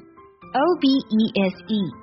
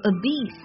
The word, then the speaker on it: obese: O B E S E 这个单词可以读作 obese，也可以读作 obese，它指的是肥胖的、过胖的 penguin 企鹅，就是你把我叫一只肥企鹅。”